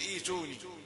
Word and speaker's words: ائتوني: لكن إذا بدأنا في بداية الكلام، يعني من الاختبار أو في يعني مقراءة أو للتعليم أو أي شيء، نقول ائتوني ولا نقول ائتوني. انتبهوا لهذا جيدا.